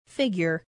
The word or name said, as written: figure